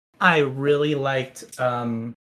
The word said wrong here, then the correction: Kuina: Kuina as a character. Yeah, that's how much I liked her. I forgot her name or I mispronounced her name. But Kuina, I really, really enjoyed her as a character.